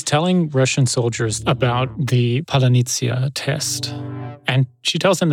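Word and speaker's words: паляниця: Palanitsia. Palanitsia.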